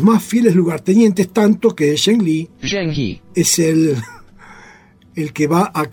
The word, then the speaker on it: Zheng He: Y entonces todo el mundo los odiaba, y este dijo, ojito, no me lo toque, y de hecho eligió a un musulmán, Zheng He. ¿Cómo se pronuncia? – Zheng He.